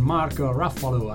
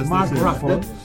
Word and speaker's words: Mark Ruffalo: Tutto può cambiare di John Carney con un Mark Ruffalo alla milionesima interpretazione. – Un po' affaticato sulla pronuncia. – Mark Ruffalo.